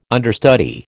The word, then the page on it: understudy: American English